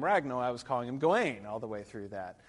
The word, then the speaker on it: Gawain: Um, but here, this is alliterative verse, and the emphasis is on the first syllable, Gawain. Um, he's clearly not Gawain, because the emphasis, the alliteration, is usually on the G, so that's what's clearly supposed to be emphasized.